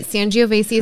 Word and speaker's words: Sangiovese: Sauvignon or Sangiovese. – You're right. Sangiovese is next yeah.